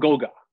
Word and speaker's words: Goga: and correct me on this pronunciation, Gogo Badazzi. Am I saying that correctly? Let's call him Goga.